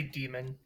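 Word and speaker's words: Daemon: And you can just say demon, even though it's spelled differently.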